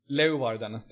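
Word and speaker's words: Leeuwarden: SE Kambur kommer ju alltså från Lojvarden, oklart hur, hur det uttalas. Leovarden till och med.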